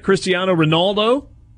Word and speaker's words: Cristiano Ronaldo: Second highest paid athlete on the planet, Cristiano Ronaldo.